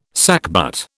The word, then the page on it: sackbut: British English